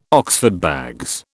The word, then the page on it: Oxford bags: British English